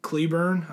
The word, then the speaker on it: Cleburne: Cleburne, I believe that's pronounced.